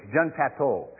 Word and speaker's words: Jean Patou: Making up some perfumes, one of the perfumes that might be more affordable to you guys is Joy by Jean Poudet, I think it is. And that particular one, it's Jean Patou. Sorry. I said it wrong, probably.